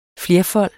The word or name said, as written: flerfold